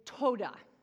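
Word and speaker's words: toda (תודה): But the Hebrew word for thankfulness is toda, is how you pronounce it.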